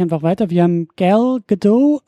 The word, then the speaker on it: Gadot: Gerdot, ja, das hat sie mal jetzt im, äh, in äh, Late Night Show mal erklärt. Äh, ich habe das auch immer so französisch ausgesprochen, aber es wird, das T ist tatsächlich hart.